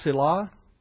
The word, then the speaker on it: Selah: It's pronounced Selah.